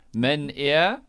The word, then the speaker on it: Meneer: How would he pronounce Meneer? Meneer? – Yes.